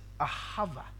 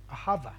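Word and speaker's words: ahava (אהבה): The Hebrew word is for love is ahava, ahava.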